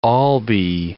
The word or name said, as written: Albee